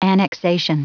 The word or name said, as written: annexation